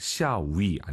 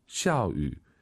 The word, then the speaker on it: 下雨: 비가 오다 샤위 안 됩니다. 비 오다 발음 다시 들어보고 싶어요. – 下雨. – 샤위 샤위 꼭 기억하시고요.